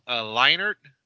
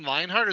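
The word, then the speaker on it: Leinart: so it's actually, I mean, for those who may not know, it's pronounced Leinert, is the quarterback, Matt Leinert,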